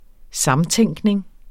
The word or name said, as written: samtænkning